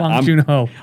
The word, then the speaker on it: Bong Joon Ho: and Bong Bong Joon from Parasite. – Bong Joon Ho.